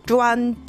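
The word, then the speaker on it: 塼: 中国語ではジュワンっていう発音なんですけれども、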